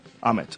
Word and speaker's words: Amit: Amit.